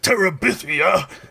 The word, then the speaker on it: Terabithia: Why did you say it like Gandalf? – Terabithia. – Because I think it sounds... Bridge to Terabithia. – So Bridge to Terabithia. – I think that's the better pronunciation, but...